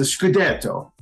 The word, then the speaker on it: Scudetto: I think you pronounced it incorrectly, Joe. It's the Scudetto. I think that's how you say it.